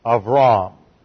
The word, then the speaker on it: Avram: Abraham was originally named Avram. And that is spelled in the Hebrew, it looks like this. And the B there is a soft B and translated almost like a V.